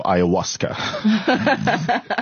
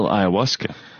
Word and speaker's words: ayahuasca: It's, are you ready for ayahuasca? – By the way, how complicated is it to spell ayahuasca?